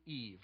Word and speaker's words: Yves: Well, it's French. It's pronounced Y-V. It's spelled Y-V-E-S, and I think it's pronounced Eve or something like it.